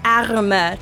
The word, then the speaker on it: Arme: Arme. – Tu dois prononcer le R. If you, it's like right in your throat. If you can't say it, it's just arms.